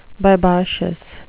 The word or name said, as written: bibacious